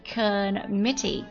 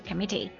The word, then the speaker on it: committee: committee 它是表示委员会的意思啊，重音在第二个音节上面。 committee C-O-M-M-I-T-T-E-E committee。